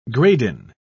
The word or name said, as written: gradin